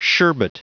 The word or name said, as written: sherbet